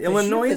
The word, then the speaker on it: Illinois: And I heard a couple people from Illinois lately pronounce the S at the end.